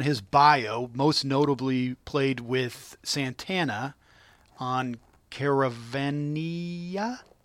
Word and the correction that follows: Caravanserai: Is that how? I don't know. We butchered things. – Caravanserai. – Caravanserai. Thank you, sir.